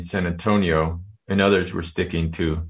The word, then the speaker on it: San Antonio: This was the summer of 1979, and most of us living on the border called the city San Antonio, as it's pronounced in Spanish, and not San Antonio, as I've heard it pronounced in other places.